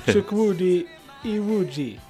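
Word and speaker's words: Chukwudi Iwuji: Chuck Woody Iwuji.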